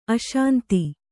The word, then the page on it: ಅಶಾಂತ: aśanta